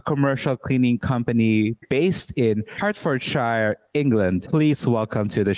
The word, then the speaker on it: Hertfordshire: It's Hertfordshire.